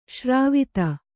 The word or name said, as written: ಸರವತ್ತು